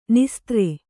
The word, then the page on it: ನಿಸ್ತ್ರೆ: nistre